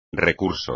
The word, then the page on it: recurso: espagnol